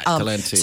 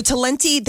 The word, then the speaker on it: Talenti: So is it Talenti? Am I saying it right? – Yeah, Talenti. – So Talenti, they have a really good peppermint bark one.